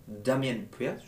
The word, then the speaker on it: Damien Priest: Diamond Priest est champion, Damien Priest, je répète, je le dis bien,